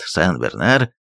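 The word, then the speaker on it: Saint-Bernard: К счастью, наш корвет Сен-Бернар, названный так в честь святого Бернарда Клервосского. Примечание. На французском название корвета пишется Сен-Бернард, но конечное «д» не читается.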